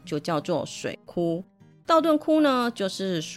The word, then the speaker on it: 道頓堀: 说到大阪，相信十个人有九个半都会想到道顿窟。我没有念错，真的是念窟而不是绝。大家会念成绝的原因是“ 窟” 这个字和“ 绝” 这个字长得真的很像，但其实是两个完全不同的字，一个是手字边，一个是土字边。